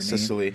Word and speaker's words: Cicely: One, Cicely Tyson, I'm not sure how to say her Cicely. – Name. Cicely, there we go, 96-year-old.